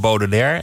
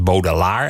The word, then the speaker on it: Baudelaire: Een gedicht van Baudelaire. En dat is dan door hem ook verkeerd uitgesproken als Baudelaar.